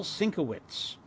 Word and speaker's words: Sienkiewicz: By the way, the co-author of Legion, the original, is the graphic artist, and I hope I pronounce this correctly, Bill Sienkiewicz. – Yes. – Sienkiewicz.